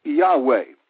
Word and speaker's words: Yahweh: So the name that is translated "I am that I am" in the Hebrew, that name is pronounced Yahweh.